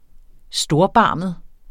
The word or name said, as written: STORBARMET